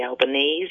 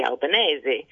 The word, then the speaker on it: Albanese: it shouldn't be Albanese, it should be Albanese.